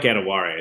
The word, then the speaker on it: Adewale: I mean, that's close. I like Adewale. I think that is correct.